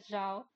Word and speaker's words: Zhao: É porque em português eles pronunciam Zal. – É, né? então. Eu tava lendo que, na verdade, a pronúncia correta é, é Jal. – É, é Jal. É, é porque o ZH em mandarim, né, fica Já.